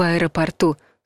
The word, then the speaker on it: аэропорту: Запоминайте, ждать в аэропорту с ударением на последнем слоге.